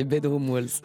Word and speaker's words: Bedroom Walls: 6 mois après la sortie de son album Bedroom Walls, la française November Ultra, oui, se moque de ma prononciation, c'est Bedroom Walls.